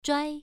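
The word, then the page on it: zhuai: tone 1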